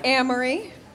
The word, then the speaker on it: Amory: My name is Amory. It rhymes with mammary.